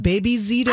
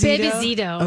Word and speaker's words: Bebe Zito: I think we're calling it Bebe Zito. – Bebe Zito? – Bebe Zito. – Okay. – Yeah. – But it's spelled like the French way. – But in Minnesota, it will turn into Baby Zito.